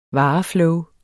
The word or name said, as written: vareflow